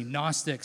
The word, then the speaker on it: Gnostic: It's this idea of a Gnostic heresy, Gnostic, silent G. It's like pneumonia or pterodactyl, but with a G.